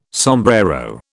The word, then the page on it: sombrero: British English